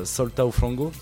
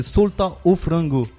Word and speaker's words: Solta o Frango: Le morceau s'appelle Solta o Frango, si je le prononce bien. – Non. – C'est Solta o Frango.